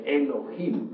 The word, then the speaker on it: Elohim: Diosas y dioses, eso significa Elohim, o mejor dijéramos Elohim, porque la H se pronuncia como J.